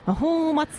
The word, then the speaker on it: 泡沫: 泡沫っていうね、私この漢字歌方という読み方しか知らなかったんですけど、泡沫とも読むんだっていうことをね、初めて、はい、この曲で知りましたね。